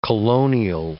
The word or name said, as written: colonial